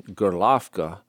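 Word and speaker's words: Gerlovka (Горловка): So there was an Institute of Higher Learning there in Gerlovka. That's the Russian pronunciation of it. It's spelled like Horlivka,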